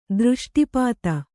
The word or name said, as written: ದೃಷ್ಟಿಪಾತ